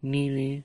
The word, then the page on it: nili: Lwaitel